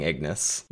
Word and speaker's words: Ignus: I have to step in here and say it's Grinning Ignus. – What am I saying? Ignus? Xtus? – Extus, Extus, Ignis.